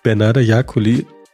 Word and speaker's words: Bernarda Jakuli: Und ähm, Bernarda Jakuli, jetzt hoffe ich, ich habe es richtig ausgesprochen. I'm so sorry if not. Daumen hoch, danke, passt.